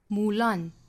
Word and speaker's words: Mulan: 在英语里呢，单词是会有重读音节，会有弱读音节的，那这个单词是体现在后面重读 Mulan da da。